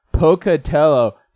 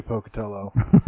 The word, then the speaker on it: Pocatello: Like, live people I talk to can't figure out how to say Pocatello. They either think it's Spanish and they pronounce the L's as a Y. I've heard that one. Pocatello.